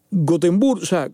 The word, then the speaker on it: Göteborg: y Göteborg, que es la manera correcta de pronunciarlo en sueco, es la segunda ciudad en importancia de Suecia.